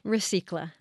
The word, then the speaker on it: Recycla: First off, how is that even pronounced? Because there were all kinds of things in the newsroom that we were kind of thinking about. – Recicla. – Recicla. Recicla.